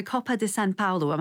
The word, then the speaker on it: Coppa di San Paolo: Now, Gerard, tell me about the Coppa di San Paolo. Am I pronouncing it correctly? I tried to put a little bit of a spin on it there. How did... is it okay? – Oh, your Italian's good.